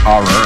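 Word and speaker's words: Ho99o9: C'est h o 9 9 o r prononcé Horror.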